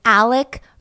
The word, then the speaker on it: Alex: Now, the one that we want to focus on is Alec Murdoch. So it's spelled Alex, but it's actually Alec Murdoch.